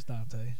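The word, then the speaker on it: Dont'a: It's pronounced Dante, right? – It's Dante. – Okay, because it's spelled Dante. – Anyway, y'all see the little? – It's there. Okay. It's there, but it's it's before the A, I guess. So it's Dante.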